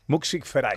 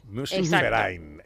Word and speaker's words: Musikverein: Musikverein. – Musikverein. – Como si fuera una F. – Musikverein.